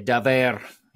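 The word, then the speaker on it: davar: Nothing too crazy, but Daver. You got to say it like that. You got to roll your R. If you want this hat, you got to say Daver, and you got to tell me what it means.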